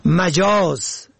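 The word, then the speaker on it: مَجاز: مجاز به فتح اول، غیر حقیقی که در تلفظ آن باید دقت شود.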